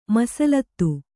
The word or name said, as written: ಮೀಸಲಾತಿ